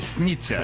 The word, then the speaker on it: снится: California snitza.